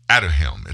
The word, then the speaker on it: Aduhelm: That drug, Aduhelm, it's spelled A-D-U-H-E-L-M. Aduhelm, A-D-U-H-E-L-M.